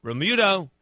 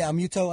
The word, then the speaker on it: Realmuto: JT Realmuto. Is that how you say his name? Realmuto? How do you say his name? Realmuto, I think.